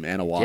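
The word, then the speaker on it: Anoa'i: But the guy behind the mask, you know, Joe Annoy, or however you pronounce his last name, Anoy. Yeah, you nailed it, Joe Annoy. Perfect.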